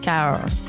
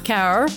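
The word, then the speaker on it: Cahors: We've got the Appellation Caor. I think that's how you say it because you're not really pronouncing the H in French. It's just like this big smear of letters. Caor.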